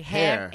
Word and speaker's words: Herr: How do you say it in German? When someone's like a sir, it's like hair. H-E-R-R, it's hair.